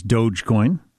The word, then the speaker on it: Dogecoin: Dogecoin is Dogecoin. Okay, thank you for that. – Not doggy coin? – Billie Eilish, for anybody who doesn't know.